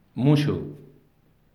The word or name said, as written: muxu